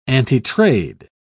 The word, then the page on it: antitrade: British English